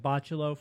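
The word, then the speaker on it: Bachalo: The artist was Chris Bachalow. I'm going to pronounce it Bachalow for the remainder of the show and stop correcting myself.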